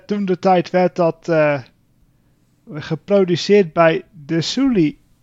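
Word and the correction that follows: Desilu: De Silu Production Studios.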